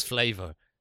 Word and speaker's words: flavor: This is flavor, but it has a U in it. You don't pronounce the U in it.